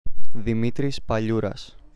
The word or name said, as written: Dimitris Paliouras